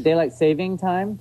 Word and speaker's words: daylight saving time: It's actually daylight saving time. There's no saving. – S. – Yes. – Daylight saving time. Well, there you go. I've been saying it wrong for 53 years.